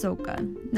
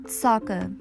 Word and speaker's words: Sokka: In the movie, it's actually pronounced Sokka, not Sokka.